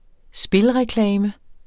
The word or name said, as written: spilreklame